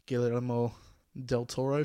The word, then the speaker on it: Guillermo del Toro: So this is a Guillermo del Toro film.